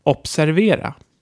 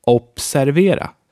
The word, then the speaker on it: observera: Observera. Observera. Do you notice the difference? That's not a B, it's so hard, it's almost a P. Try it out. Observera.